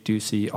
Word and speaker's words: Ducey: D-U-C-E-Y. And you're on Facebook and Twitter at Jake Ducey Author.